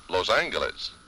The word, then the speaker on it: Los Angeles: This is KNX, Los Angeles. – From November 1st, 1947, as you noticed, they were still pronouncing that hard G in Los Angeles.